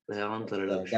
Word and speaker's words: Léandre Larouche: Léandre Larouche.